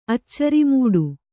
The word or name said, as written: ಅಚ್ಚರಿಮೂಡು